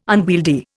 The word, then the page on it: unwieldy: Indian